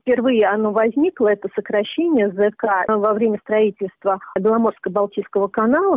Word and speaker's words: ЗК: И уже в официальной лагерной документации появляется вот это вот сокращение ЗК. Именно так это произносилось.